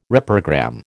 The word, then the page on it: reprogram: American English